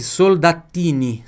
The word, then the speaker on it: soldatini: Duas pronúncias que você vai ter que prestar atenção aqui. No L. Porque L tem som de L na língua italiana. Ah, mas você vai dizer assim, sempre? Não, lógico, não. Em português, não. Em português, L tem som de U. Soldatini. E a pronúncia pura do T aqui, ó.